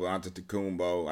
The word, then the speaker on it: Antetokounmpo: And the fact that, he jumped over Antetokounmpo.